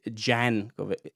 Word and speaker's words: Jankovic: I say Jankovic. That's the way she pronounced it. Actually, I, I found it in an interview.